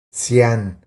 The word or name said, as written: Cian